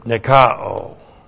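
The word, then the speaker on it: Nikao (νικάω): Nikao. N-I-K-A long O. Nikao.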